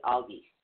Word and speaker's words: Elhaz: The next one is Elhaz. It's actually spelled E-L-H-A-Z, but it is pronounced A-L-L-G-E-E-Z-E, Elhaz.